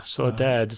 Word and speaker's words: saudade: Five, Saudade. I guess that's how you say it. Yeah. – Saudade.